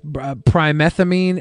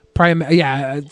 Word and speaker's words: Pyrimethamine: It entered exclusivity agreements with the suppliers of a critical input known as primethamine API. – Pyrimethamine.